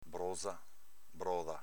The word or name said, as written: broza